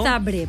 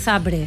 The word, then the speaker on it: Zabrze: ¿Por qué sabes que es Zabrze? ¿Por qué pronuncias porque lo sé. – Zabrze? – Porque es así.